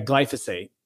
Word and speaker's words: glyphosate: And now Organifi has gotten rid of glyphosate. – Yeah, glyphosate. I know. When I first started saying this, I said it every time. My team was like, hey, this is how you say it.